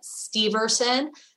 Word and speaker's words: Steverson: My name is Ellen Steverson. A lot of people misspell that last name. So it's S-T-E-V-E-R as in Romeo, S-O-N.